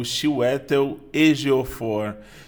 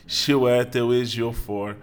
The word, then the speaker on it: Chiwetel Ejiofor: Salve, salve pessoal com a pronúncia certa desse ator incrível que é o Chiwetel Ejiofor. Chiwetel Ejiofor.